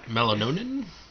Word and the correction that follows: melanoidin: Melanoidin. – Melanoidin.